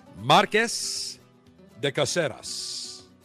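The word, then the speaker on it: Marqués de Cáceres: It is called Marques de Caseras. Marques de Caseras. I have to pronounce it with the correct pronunciation.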